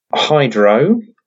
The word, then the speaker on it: HDRO: So the ticker on this is Hydro, not with a Y. HDRO is the ticker. It's quite clever, that, isn't it? Hydro. You pronounce it Hydro, but it's got no Y in it.